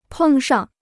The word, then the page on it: 碰上: pèng shàng